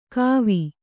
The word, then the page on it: ಕೋವ: kannada